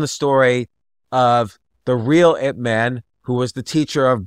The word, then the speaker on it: Ip Man: And it's called Ip Man. It's spelled I-P space man, M-A-N.